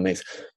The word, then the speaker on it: Myss: Yeah, well, one of them I stole from Carolyn Mace, one of my favorite spiritual teachers, and it's spelled M-Y-S-S, but pronounced Carolyn Mace.